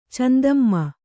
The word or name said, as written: ಚಂದಮ್ಮ